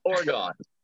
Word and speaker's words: Oregon: It's pronounced like you lost an oar on a canoe. – Oregon.